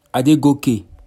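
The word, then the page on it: Adegoke: Yoruba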